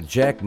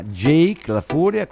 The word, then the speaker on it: Jake La Furia: Va bene, lui lo conoscete tutti, bisogna stare molto attenti alla pronuncia perché non si può dire Jack, ma Jake la Furia.